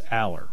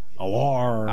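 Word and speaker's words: Allar: It's like Drew Aller. It's pronounced Aller. I've heard everything. Alar. – I have too. – Yeah, it's like, it's Aller.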